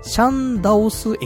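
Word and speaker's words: 善導寺: 善道寺駅って漢字で書くんだけど、読み方としては、シャンダオス駅ってところのすぐ近くにあるらしくて。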